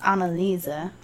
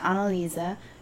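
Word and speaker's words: Anneliese: First of all, I think it's Annalisa. – Okay. – If there's an E at the end. – Mm-hmm. – Yeah, Annalisa. And then, how do you spell that? – Like Mitchell. – Oh. – M-I-C-H-E-L.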